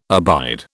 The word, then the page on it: abide: British English